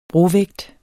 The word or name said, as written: brovægt